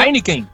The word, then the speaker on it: Heineken: É o nome assim mesmo, é que pronuncia? – Exatamente. – Heineken.